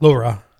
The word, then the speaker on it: LoRa: LoRa.